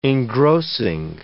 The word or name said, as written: engrossing